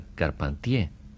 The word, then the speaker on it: Carpentier: Yo creo que a las orejitas mexicanas no es necesario explicarles quién es don Alejo Carpentier, que en realidad debemos pronunciar Carpentier, dado que su padre era francés.